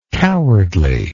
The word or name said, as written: cowardliness